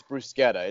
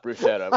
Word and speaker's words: bruschetta: So I'm the college kid who went on, did the one semester study in Rome, and it's like, actually, it's bruschetta. It's not bruschetta. I've been pronouncing it wrong.